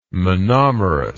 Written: monomerous

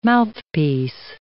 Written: mouthpiece

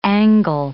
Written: angle

